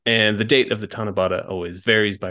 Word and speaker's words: Tanabata: And then somehow when you put them together, it comes to Tanabata.